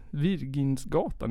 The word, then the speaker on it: Virginsgatan: Virgingatan i Göteborg. Bor han där? – Virgingatan? – Ja. Virginsgatan. Det kan inte uttalas på annat sätt än Virginsgatan.